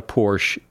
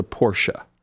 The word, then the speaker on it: Porsche: The car is a Porsche. It is not a Porsche. It is a Porsche.